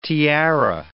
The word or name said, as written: tiara